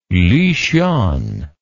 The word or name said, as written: luxian